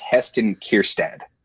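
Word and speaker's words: Heston Kjerstad: From what I understand, it's Heston Kerstad. – Okay. – Okay. So it's more of a short A than the long A at the end.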